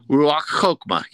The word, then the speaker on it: Ruach Chokmah: And then it would say Ruach Chokmah. Is that, I'm saying that right, sir? – Ruach Chokmah. Yes.